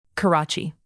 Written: karachi

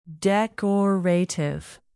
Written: decorative

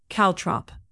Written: caltrop

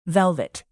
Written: velvet

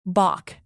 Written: baulk

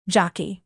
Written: jockey

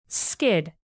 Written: skid

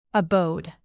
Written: abode

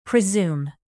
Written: presume

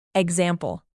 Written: example

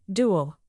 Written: dual

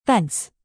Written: fence

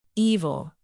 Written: evil

